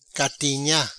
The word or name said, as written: Gatinha